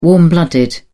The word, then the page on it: warm-blooded: British English